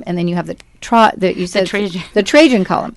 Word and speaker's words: Trajan: Is it pronounced Trajan? – It's the Trajan Column, Trajan.